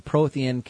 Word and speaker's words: Prothean: The Promethean or the Prothean character, pronounced it wrong, the Prothean character as well as its side quest, its side story.